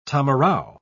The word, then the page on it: tamarau: British English